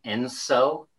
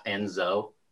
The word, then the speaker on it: ENSO: So can we agree that the proper way to pronounce the El Nino La Nina index is Enso, not Enzo? – Ooh. – I've never heard it pronounced Enso. – I think Enso is right. There's Enzo Ferrari. You may be getting it confused with Enzo Ferrari.